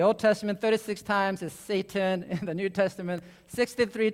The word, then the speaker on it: Satan: Satan is actually pronounced Satan, which would be the Hebrew version.